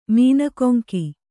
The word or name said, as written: ಮೀನಕೊಂಕಿ